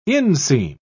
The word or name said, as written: inseam